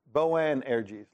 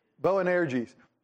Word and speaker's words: Boanerges: Boanerges, like bow and arrow with a G's at the end. Thank you, Tom. Boanerges.